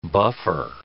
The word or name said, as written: buffer